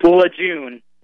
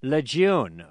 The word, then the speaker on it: Lejeune: Camp Lejeune. – Lejeune, all right.